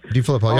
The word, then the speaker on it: DeFilippo: I had a whole problem with the sign of John DeFilippo. Is that how you say his name? – Yep, DeFilippo. Yep.